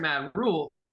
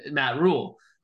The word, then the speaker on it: Rhule: When they hired Matt Rhule, you can say it. I'm saying it the right way. Matt Rhule. – Matt Rhule. Thank you. Say his name correct.